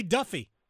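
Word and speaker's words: Daffue: I'm thrilled for MJ Defew. I'm sure I'm pronouncing his name wrong. I was joking with another writer that Duffy. It's actually Duffy. – Is it Duffy? – Yeah.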